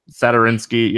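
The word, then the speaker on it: Satoranský: We're done playing those two value guys, Sanarisky, Satoransky, or whatever the heck his name is. Satoransky, yeah, whatever. I'm looking right at his name, and I was still saying it wrong for some reason.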